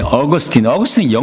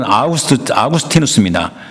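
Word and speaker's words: Augustinus: 이제 어거스틴이 영어 발음입니다. 원래는 아우구스티누스입니다. 어거스틴이 영어식 발음인데 어거스틴이 죽을 때는요.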